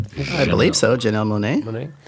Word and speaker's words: Janelle Monáe: Last but not least, Janelle Monáe. Am I saying that right? – Janelle Monáe.